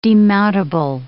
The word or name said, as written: demountable